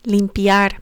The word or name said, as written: limpiar